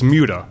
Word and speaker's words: muda: And it adds waste and cost into the process, what the world-renowned Toyota production engineer Taiichi Ono referred to as MUDA, that's spelled M-U-D-A, which is any activity that adds waste and cost but does not add value.